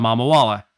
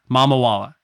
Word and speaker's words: Mamawala: Adam Mamawala. He's like, just your last name. I was like, Mamawala.